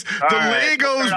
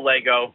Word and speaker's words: Lego: I didn't know this was a thing because Legos pronunciation drives me insane. – Yeah, we just call Lego. We just say Lego. We'll start off with some Monday controversy, but yeah, just Lego. No S needed.